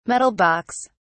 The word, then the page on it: metal box: British English